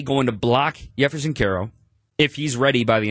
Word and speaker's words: Jefferson Caro: The J is silent, Spanish pronunciation, Jefferson Caro.